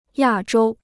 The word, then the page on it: 亚洲: yà zhōu